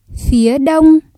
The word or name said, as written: phía đông